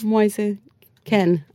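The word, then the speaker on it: Moise Kean: Moise Keane? – It's close enough. – Moise Ken, actually. – Ken. – Anyway. Moise Keane. – But it's spelled Keane for some reason, so I get that.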